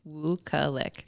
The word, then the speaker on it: Wukelic: Wukelic.